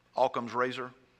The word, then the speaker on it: Occam's razor: Are you, any of you familiar with a term, and I think it's pronounced Occam's razor? Occam's razor, spelled O-C-C-A-M-S.